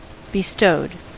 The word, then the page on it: bestowed: American English